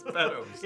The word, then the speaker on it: Beddoes: Beddoes, that's the one, it's Beddoes. – Yeah, now that you said it right, Beddoes is like, yes, sir.